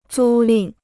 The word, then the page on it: 租赁: zū lìn